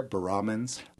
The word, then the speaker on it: baramins: So baramins, and you emphasize the second syllable there, baramins? – Yes.